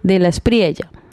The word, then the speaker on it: de la Espriella: Pero como estamos en Latinoamérica, donde la doble L, la Y, se pronuncia como Y prácticamente, entonces de la Espriella.